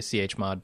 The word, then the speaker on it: chmod: Right A lot of hacking done in directory lists and cmod and stuff like that, but or chmod, how do you pronounce that? – I'd say chmod, but that's cumbersome.